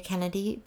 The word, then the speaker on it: Kenady: I'm under Carrie Kenady, Pet Psychic. My last name is spelled a little bit funny. It's K-E-N-A-D-Y.